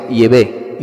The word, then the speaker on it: Yahvé: en vez de decir Jehová, pues no diga Jehová, pues di la pronunciación más probable, que es Yahvé.